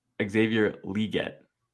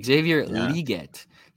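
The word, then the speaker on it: Xavier Legette: Also, real quick, bro, the correct pronunciation of his name, he corrected us there, Xavier Leggett. – Oh, emphasis on the E. Okay, Xavier Leggett.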